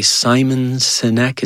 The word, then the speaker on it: Simon Sinek: Simon Sinek, is that how you say his name? – Mm-hmm.